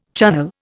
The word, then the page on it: Chunnel: Indian